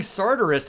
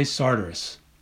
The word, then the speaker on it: Sartoris: and the people at the Faulkner Conference in Oxford say Sartoris. – Mm. Too bad for them. I'm not changing. – I know. Okay. Well, I've been told that Sartoris is in effect my Yankee pronunciation, and I'm gonna go with the local version.